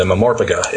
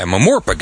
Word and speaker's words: MMORPG: They're making Knights of the Old Republic the MMORPG, the Mamorpga, if you will. – Yeah, Mamorpga, that's how you say it. I needed a kid on here to tell me how to say that.